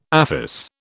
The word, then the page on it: aphis: British English